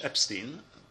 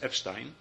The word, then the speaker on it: Epstein: And suddenly, during the job interview, Epstein has, they pronounce it in English, Epstein. In German, you would pronounce it Epstein.